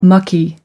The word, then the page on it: mucky: American English